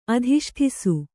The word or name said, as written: ಅಧಿಷ್ಠಿಸು